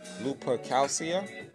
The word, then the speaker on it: Lupercalia: It was called, and I know I'm going to botch up the wording or how to pronounce it, but it's Lupercalcia. Lupercalia.